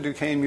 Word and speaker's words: Duquesne: I'm sorry, Bob, it's called pronounced Duquesne, not Duquesne, but we usually call it that as a joke, generally. So I've heard that many times. We both went to Duquesne Music School, I think about, for me, 30 years later.